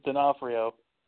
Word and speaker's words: D'Onofrio: If you say it the proper Italian way, it's D'Onofrio.